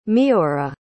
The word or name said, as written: meora